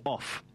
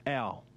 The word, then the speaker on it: Ough: We've been told it's pronounced ow, as in ow when you cop a blow to the arm or something. Ow! That's how you pronounce it. But it would appear as though it reads like off. – O-U-G-H.